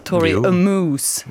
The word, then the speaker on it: Tori Amos: Men jag vet inte om jag uttalade det rätt. Hur hade du sagt? Tori Amos. – Jo, det tror jag man kan säga.